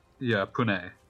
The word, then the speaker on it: Pune: And I'm checking the pronunciation on that, because I'm not convinced that I'm pronouncing that correctly. I wanna be respectful here. And Pune. Okay, excuse me.